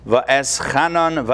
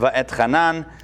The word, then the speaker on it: Va'etchanan: Torah portion of this week is Va'eschanan, Va'etchanan, depending on how you pronounce it.